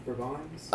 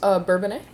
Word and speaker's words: Bourbonnais: Looks I can never pronounce it's Bourbon. Bourbons. – Bourbonnais.